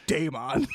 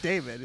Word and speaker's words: Daemon: The only one I can remember is Daemon, because I think it's pronounced Daemon. – Because in your head you just think Game On, because you're a gamer. – Yeah, no, because I just think like Damon. His name's just Damon, but they put an E in it.